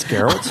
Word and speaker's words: Geralt: It's Geralt.